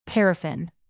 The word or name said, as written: paraffin